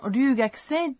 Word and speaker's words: ryūgakusei: On prononce ryū dans ryūgakusei d'une manière similaire au son L, même si en français on l'épelle avec un R.